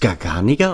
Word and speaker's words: Garganega: Wird es Garganega ausgesprochen? Ich habe das immer Garganega gesagt. – Ja, ich habe mich da mal von einem Italiener belehren lassen.